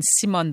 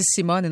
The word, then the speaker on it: Simone: Est-ce que vous avez fait comme une Simone Biles? En passant, apparemment qu'on dit Simone et non pas Simon.